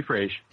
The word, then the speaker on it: Fraize: You call me Fraize. – Fraize. It's that zh sound that's getting me, all right? – Yeah.